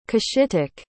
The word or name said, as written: cushitic